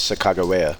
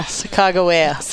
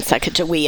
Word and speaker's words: Sacagawea: But I guess the correct pronunciation of her name is Sacagawea. – Yeah. – Sacagawea. Which makes way more sense. – Yeah. – Than Sacagawea.